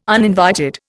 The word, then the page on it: uninvited: Indian